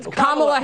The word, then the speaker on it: Kamala: Kamala Harris.